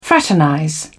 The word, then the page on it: fraternize: British English